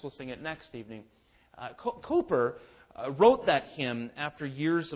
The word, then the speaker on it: Cowper: It looks like William Cowper, but it's pronounced Cooper, with which many of us are probably familiar.